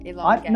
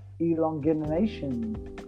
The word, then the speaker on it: elongation: What was that word? Elongation? How do you say it? – Elongation. – No, you didn't say it wrong. I said it wrong. I was trying to sound sexy when I said it. Like, elongination.